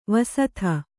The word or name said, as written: ವಸುಧೆ